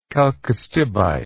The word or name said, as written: chalcostibite